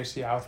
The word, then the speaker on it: Seau: I would say the same thing that happened with, I don't know how to pronounce his name, Junior Seau? – I believe it's Seau, yeah.